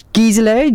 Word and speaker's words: Gizela: People always get my name wrong, okay? Like Gisela, G-I-Z-E-L-A. It's either spelt wrong or it's said wrong, but it's always some kind of wrong.